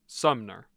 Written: Sumner

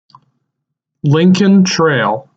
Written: Lincoln Trail

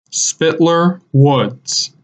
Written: Spitler Woods

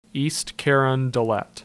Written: East Carondelet

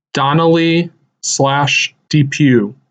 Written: Donnelley DePue

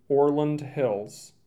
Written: Orland Hills

